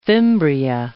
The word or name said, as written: fimbria